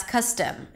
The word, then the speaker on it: custom: Okay, to pronounce the word custom and customer, you start with a cup sound, cuss, right? So it's very similar to costume. But here it's not the ah as in father, so it's not cos, it's cus, cus.